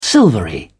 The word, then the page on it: silvery: British English